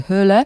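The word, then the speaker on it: Hole: Podobno Jones, bo nie obraża się, kiedy czyta się nazwisko jego bohatera z angielska Harry Hall, ale ja postaram się jednak z norweska Harry Hyle.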